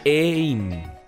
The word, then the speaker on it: AIM: AIM. – E-I-N. – Bueno, la pronunciación es en inglés. A-I-M-M. AIM.